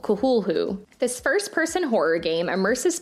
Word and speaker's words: Cthulhu: According to Wikipedia, it is pronounced Cthulhu.